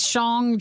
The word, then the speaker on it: Shang-Chi: We're still a couple weeks away from the release of Marvel's Shang-Chi. Am I saying that right? – It is Shang-Chi.